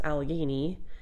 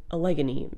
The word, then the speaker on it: Allegheny: I did not know how to pronounce Allegheny, but I actually did some YouTube video pronunciation research. I kept calling them Allegheny Mountains, but it's Allegheny Mountains.